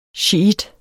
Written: shiitter

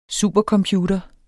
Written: supercomputer

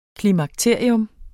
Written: klimakterium

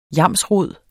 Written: yamsrod

